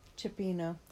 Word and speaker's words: cioppino: C-I-O-P-P-I-N-O. – Cipino. – Thank you. I never say it right.